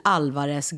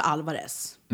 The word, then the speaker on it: Álvarez: Arantxa Isabel Alvarez Garri. Eh, ja, men jag goes by Arantxa Alvarez. – Mm. – Mm. Och man har betoningen på Alvarez, inte Alvarez. – Nej, utan exakt. Alvarez.